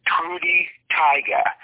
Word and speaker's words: Trude Teige: And, of course, Gunnar, who's been at it since the early 1970s, and a woman named Trudy Tyga, which is spelled T-R-U-D-E, T-E-I-G-E.